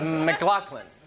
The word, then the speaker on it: McLaughlin: Is your name pronounced McLaughlin? – It's McLaughlin. Close. It's Scottish. Yeah. – Yeah,